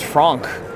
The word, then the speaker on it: Franck: Well, when I found out Frank Miller was Franck, I was amazed. Yeah, he pronounces it the German way.